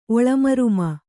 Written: ಒಳಮರ್ಮ

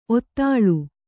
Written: ಔತಳ

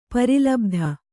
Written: ಪರಿಲಬ್ಧ

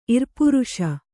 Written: ಇರ್ಪುರುಷ

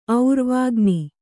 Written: ಔರ್ವಾಗ್ನಿ

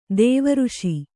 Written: ದೈವರೇಷೆ

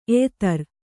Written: ಎಯಿತರು

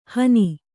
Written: ಹನಿ

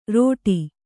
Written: ರೋಟಿ

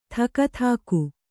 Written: ಥಕಥಾಕು

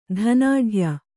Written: ಧನಾಢ್ಯ

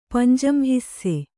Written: ಪಂಜಂಹಿಸ್ಸೆ

